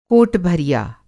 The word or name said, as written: कोट-भरिया